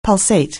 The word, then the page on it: pulsate: anglais